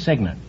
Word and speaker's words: cygnet: It's spelled C-Y-G-N-E-T, but it's pronounced Signet.